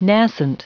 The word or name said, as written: nascent